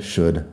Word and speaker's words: should: porque se escriben parecidas y todos tienen la L que no suena y el mismo sonido de la U corta. Así que would, could, should.